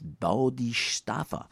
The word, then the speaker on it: Bodhisattva: Can you say that? It's Bodhisattva.